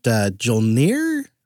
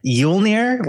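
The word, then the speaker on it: Jólnir: Is it Jolnir? – So that's the fun part is none of us know how to pronounce it correctly. I think it's like Yulnir, like Yuletide, I think.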